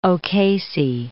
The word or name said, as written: O'Casey